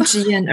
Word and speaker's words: Jian: I tell people the pronunciation of my name is like good night, G-N. And that's why when they work with me, they have good night's sleep. I'm Jian, originally from Shanghai.